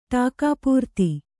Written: ಟಾಕಾಪೂರ್ತಿ

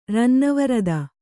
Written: ರನ್ನವರದ

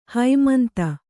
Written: ಹೈಮಂತ

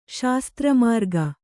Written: ಶಾಸ್ತ್ರಮಾರ್ಗ

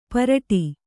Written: ಪರಟಿ

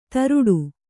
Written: ಧಾರಾಟ

